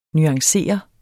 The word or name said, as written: nuancere